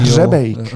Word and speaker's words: Hřebejk: A slávny režisér Jan Hrebej, Hre, nie Hrebeň, Hrebejk, nakrútil... – Hřebejk, hřebejk má tam. Hř, aha, to r neviem vysloviť.